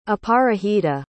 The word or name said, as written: aparajita